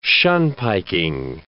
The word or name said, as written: shunpiking